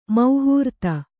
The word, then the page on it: ಮಹಾರಥ: Kannada